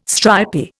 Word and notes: stripy: Indian

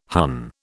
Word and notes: Hun: British English

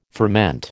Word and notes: ferment: American English

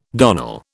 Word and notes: darnel: British English

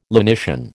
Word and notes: lenition: American English

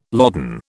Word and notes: loden: British English

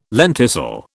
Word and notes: lenticel: British English

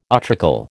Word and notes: utricle: American English

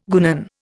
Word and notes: guenon: Indian

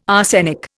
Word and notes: arsenic: Indian

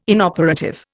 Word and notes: inoperative: Indian

